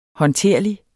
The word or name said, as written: håndterlig